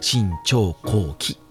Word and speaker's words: 信長公記: でこの太田牛一が、まあ、なぜその記録をね取ってる記録まで分かるかと言いますとこの「新潮後期」っていうのはですねあの漢字で書くとですね信長公信長のあと公と書いて信長公信長公の記録と書いて新潮後期なんですね。